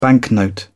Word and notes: banknote: British English

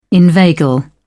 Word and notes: inveigle: British English

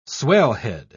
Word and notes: swellhead: British English

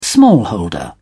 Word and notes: smallholder: British English

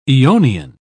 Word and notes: aeonian: British English

